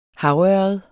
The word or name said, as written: havørred